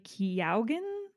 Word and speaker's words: Keoghan: Well, this guy, I believe it's pronounced Barry Keoghan. I don't know. He's Irish. – You're butchering this. I was just about to say, there's some Irish listeners yelling through their headphones right now at us. – Keoghan. I'm literally just Googling this and reading what they've written.